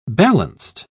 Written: balanced

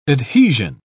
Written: adhesion

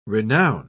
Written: renown

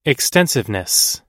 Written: extensiveness

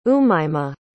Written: umaymah

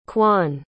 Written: kwan

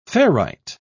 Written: ferrite

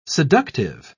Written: seductive

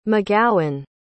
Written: mcgowan

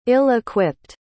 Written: ill-equipped

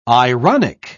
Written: ironic

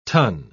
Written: tun

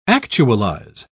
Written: actualize

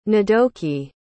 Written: ndoki

